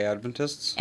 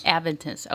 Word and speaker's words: Adventists: Seventh-day Adventists? – Adventists, okay.